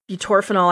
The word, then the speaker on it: butorphanol: I butorphanol, I, I still can. It's still torp but I'm pretty sure I did not pronounce that correctly. Nope, that was right.